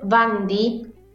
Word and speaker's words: Bundy: De los psicópatas, incluso para, que lo visualicen más a esto que dice Katy, eh, si pueden googleen, eh, Ted Bundy Bundy se escribe B larga U N D Y.